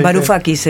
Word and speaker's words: Varoufakis: Varoufakis, el que era ministro de Varoufakis.